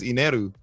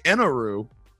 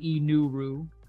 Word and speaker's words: Eneru: So when you say, if you try to say Enel or Enel in a Japanese accent, it comes out as Ineru. – Ineru. – And so in the dub, they say Eneru.